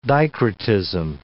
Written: dicrotism

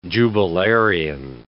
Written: jubilarian

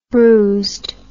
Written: bruised

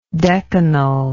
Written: decanically

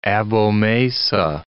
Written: abomasa

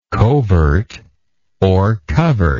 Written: covertly

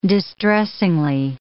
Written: distressingly

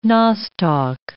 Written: nostoc

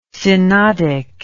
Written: synodic

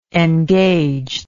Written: engagedness

